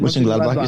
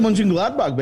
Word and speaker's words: Mönchengladbach: Mönchengladbach. How do you pronounce that? – Yeah, that's it. – Mönchengladbach, you said it? Is that it? – Yeah. – I like Mönchengladbach, baby.